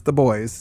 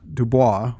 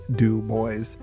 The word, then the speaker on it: Du Bois: And it is pronounced Du Bois, not Du Bois, which I almost insisted on it pronouncing in that way. But he said in a letter written in 1939 that the pronunciation of my name is Du Bois, with the accent on the last syllable.